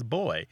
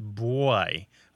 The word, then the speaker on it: buoy: Almost everyone there pronounces the word B U O Y as boy, although some say it as boy, almost as if there's a W after the B, although it's less common.